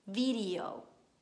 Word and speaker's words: video: La parola video in italiano si pronuncia video, video. Quindi la E viene pronunciata I, video.